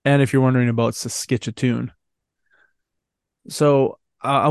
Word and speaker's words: Saskatchewan: How does he say it? Why can't I pronounce things right? – Saskatchewan. – Saskatchewan. – Hey, ladies, I'm Saskatchewan. Hey, it's in Canada. – That's perfect.